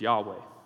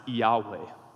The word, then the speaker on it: Yahweh: However, almost every modern scholar believes that the best guess for the pronunciation of the name is Yahweh. And this mostly comes from early Greek writings that predate the vowels that were put into the Hebrew manuscripts in like the 600s AD. This goes back to like 1st, 2nd century AD. And these Greek early Christians were saying the personal name of God is pronounced Yahweh.